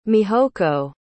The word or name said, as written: mihoko